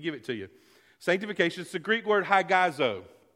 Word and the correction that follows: hagiazo: Or hagiazo, rather, mispronounce it.